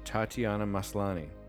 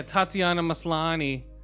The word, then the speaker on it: Tatiana Maslany: Tatiana Maslany. – Yeah, Tatiana Maslany.